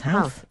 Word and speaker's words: Howth: It was actually filmed in Howth. Howth, is that right? Which is on the outskirts of Dublin. Is that correct? – Yes, Howth.